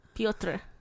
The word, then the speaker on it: Piotr: Piotr. Pio. And then tre.